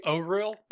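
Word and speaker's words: Auril: Fun fact, it's actually Oriel. – Oriel. That's a good point. – According to the pronunciation guide, they put one in here. Good for them. – Yeah, it's O-H-R-E-E-L.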